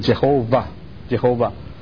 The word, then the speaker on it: Jehovah: 因为这个 W，德国人很多犹太人都到德国，这个 W 在 pronounce 的时候是 V，是 Jehovah，但是我们中文圣经就用这个耶和华，耶和华。